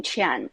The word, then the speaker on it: Qian: My name, Qian, has the same pronunciation in Chinese as money.